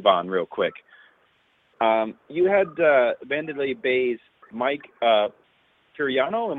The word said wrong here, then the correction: Perenio: Am I saying that correctly? – Perenio. – Yeah, Perenio. Sorry.